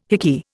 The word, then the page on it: hickey: Indian